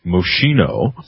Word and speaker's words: Moschino: Apparently, this new fashion by this designer called I believe it's pronounced Moschino, introduced this in New York during Fashion Week.